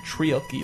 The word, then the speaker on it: treacly: Treacly? – Treacly, like treacle.